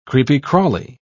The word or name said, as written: creepy-crawly